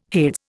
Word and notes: Hades: Indian

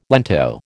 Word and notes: lento: American English

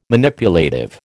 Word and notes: Manipulative: American English

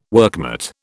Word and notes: workmate: British English

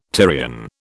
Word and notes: Tyrian: British English

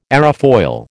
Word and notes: aerofoil: American English

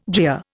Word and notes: jeer: Indian

